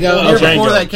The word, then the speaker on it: Django: The D is silent. – Django.